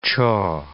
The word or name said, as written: chaw